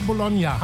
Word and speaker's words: Bologna: Jetzt Musik von Wanda Bologna. Habe ich das richtig ausgesprochen, Bologna? – Ja, hast du gut gemacht.